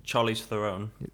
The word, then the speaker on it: Charlize Theron: Ben Stiller, Kristen Wiig, the Charlize Theron. I can never say her name. – Charlize Theron.